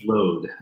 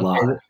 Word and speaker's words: Lod: I think it's Lode and not Lod. – Robin Lode. I've said Lod my whole life because I haven't watched it.